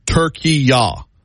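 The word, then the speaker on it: Türkiye: Deborah says they've reverted back to their original pronunciation. They reverted back to their original name a while back, she said. Turkey-ya, with a Y-A-H on the end.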